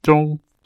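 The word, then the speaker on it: zhong (中): For example, the character for middle, which in Mandarin is pronounced something like zhong, is basically a rectangle with a line through the middle.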